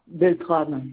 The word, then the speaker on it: Beltrame: Beltrame, comme ça se prononce, Beltram.